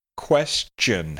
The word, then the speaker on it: question: Question has a short E on the first syllable, and then you have to be careful because even though it's T-I-O-N, you don't have your typical shun sound, like, for example, information or altercation. In this case, question has more of a ch sound in it.